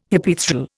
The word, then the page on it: Hypaethral: Indian